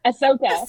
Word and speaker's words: Ahsoka: Asuka? – Ahsoka? – Ahsoka? – Ahsoka. Yeah.